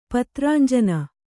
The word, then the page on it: ಪತ್ರಾಂಜನ: kannada